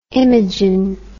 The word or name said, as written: imogen